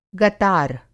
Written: गतार

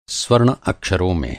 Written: स्वर्ण

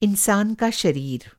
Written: इंसान

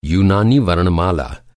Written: यूनानी

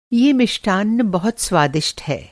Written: मिष्ठान्न

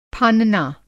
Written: फानना

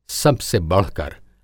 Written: सबसे बढ़कर

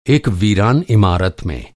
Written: इमारत

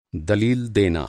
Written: दलील

